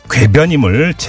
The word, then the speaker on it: 괴변: 이 전두환 씨를 비롯한 신군부는 광주 유혈 진압을 자위권 발동 차원이라고 강변해 왔는데 이것이 일고의 가치도 없는 개변. 아, 죄송합니다. 발음이 샜네요. 개변이 아니라 괴변임을 재확인하는 발표이기도 합니다.